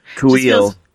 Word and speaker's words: Kuiil: And, Kuiil. Is that how we're pronouncing him? – I love that pronunciation. – Kuiil.